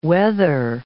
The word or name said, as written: wether